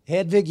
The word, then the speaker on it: Hedwig: And you actually pronounce it as Hedwig. – Hedwig, yeah, that's how you pronounce it. – Pronounce it in German.